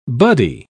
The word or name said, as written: buddy